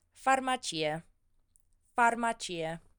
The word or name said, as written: farmacie